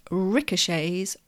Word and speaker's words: ricochet: It ricochets off the walls. The spelling is R-I-C-O-C-H-E-T, ending in the letter T. Because it comes from the French, we don't pronounce the T.